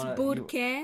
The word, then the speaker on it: Burke: It's Burke. It's not Berkey. – It's what? – The boys say it like that. The white boys. – What do you guys? Is Burke? – Burke. – Burke. You have to roll your R.